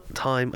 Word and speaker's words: thyme: But a thyme, time, I never say that right. – Time. – Time. It's because I'm really conscious of my THs because I'm... – The H in it is silent. – I know.